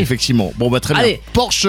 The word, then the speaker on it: Porsche: Ouais, mais on le prononce mal. Parce que normalement c'est Porsche. – Porsche. – Pas Porsche. Bah Porsche. Oui, allemand.